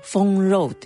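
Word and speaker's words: 封肉: えー、一番有名なのがフォンローという封じる肉と書いて、あまあドンポーローみたいなああいうものです、ね、豚肉。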